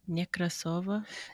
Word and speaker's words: Nekrasova: Say that last name again. – Nekrasova. – Yeah, I can't do that. It's another rolling of the R. That's why I can only speak English. – Nekrasova.